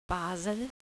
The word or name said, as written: Basel